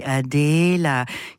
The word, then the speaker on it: Adéla: Yes, Adela. Let me say it correctly. Adela.